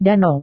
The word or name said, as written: danau